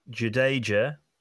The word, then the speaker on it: Jadeja: So I have got Jadeja, Pat Cummings, Mohammed Shami. – Jadeja, for those who want it pronounced correctly, Stark, Shami, 10 and 11, huge.